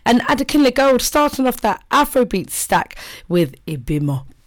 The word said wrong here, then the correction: Obimo: Did I say it right? No, Obimo. There you go.